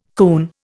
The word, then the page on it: coon: Indian